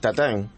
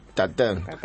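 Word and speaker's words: tatin: Tatín se escribe. – Tarta tatín es tatén. Tatán. Yo estoy de francés.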